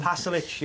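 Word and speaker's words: Pašalić: You know how to pronounce his name? – Pasalic, yes, Pasalic.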